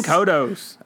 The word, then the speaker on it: Kodos: I'm talking Kang and, is it Kodos? – Yes. – Is that how you say it? – Yes, it is. Yeah, Kang and Kodos.